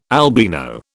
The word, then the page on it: albino: British English